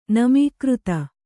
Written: ನಮೀಕೃತ

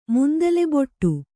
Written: ಮುಂದಲೆಬೊಟ್ಟು

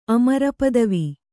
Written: ಅಮರಪದವಿ

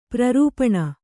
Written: ಪ್ರರೂಪಣ

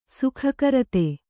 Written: ಸುಖಕರತೆ

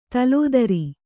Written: ದಿಲ್ದಾರ

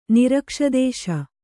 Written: ನಿರಕ್ಷದೇಶ

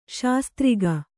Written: ಶಾಸ್ತ್ರಿಗ

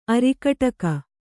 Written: ಅರಿಕಟಕ

